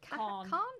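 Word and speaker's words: Kahan: Noah, is it Cahan? – Khan. Noah Cahan. Season of the Sticks. Cahan.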